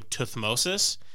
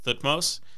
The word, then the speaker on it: Thutmose: Some people, particularly British, pronounce his name Thutmosis, but it seems like a lot of modern anthropologists pronounce it Thutmose, so I'm going to go with Thutmose.